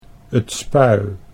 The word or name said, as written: 't Spui